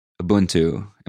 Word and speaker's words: Ubuntu: G-E-T-R-U-D-E. Here you go. Take it away. Ubuntu.